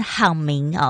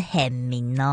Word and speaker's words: 很明: 你现在所收听的节目是时时好心情，这首歌来自浅提的很明，每次都差点要念成好明哦，是很明哦。